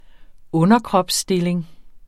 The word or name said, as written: underkropsstilling